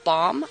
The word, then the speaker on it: Baum: L. Frank Baum, I believe that's how you pronounce his last name. – That's right.